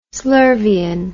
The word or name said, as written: slurvian